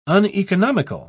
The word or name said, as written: uneconomical